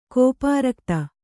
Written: ಕೋಪಾರಕ್ತ

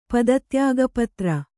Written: ಪದತ್ಯಾಗಪತ್ರ

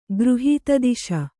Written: ಗೃಹೀತದಿಶ

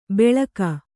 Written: ಬೆಳಕ

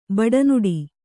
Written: ಬಡನುಡಿ

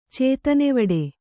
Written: ಚೇತನೆವಡೆ

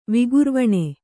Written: ವಿಕುರ್ವಾಣ

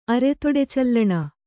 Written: ಅರೆತೊಡೆಚಲ್ಲಣ

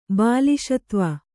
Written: ಬಾಲಿಶತ್ವ

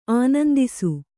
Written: ಆನಂದಿಸು

